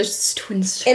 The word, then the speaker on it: Twinsters: Okay, that is how you say it because I always see that on Netflix and I'm like, is it Twin Sisters or Twinsters? – It is literally so good.